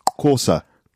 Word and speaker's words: Xhosa: It's spelled X-H-O-S-A. – And Corsa. – X-H is Co.